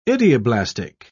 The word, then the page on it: idioblastic: British English